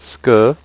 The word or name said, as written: ce que